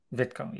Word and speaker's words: Vitko: Vitko, yes.